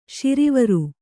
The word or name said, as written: ಶಾರ್ವರಿ